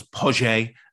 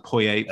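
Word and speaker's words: Poyet: A few additions, but the big news that summer, of course, was that Dennis Wise was taking over with Gus Poje, as it's pronounced, Poye for the rest of us.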